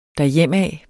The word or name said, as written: derhjemad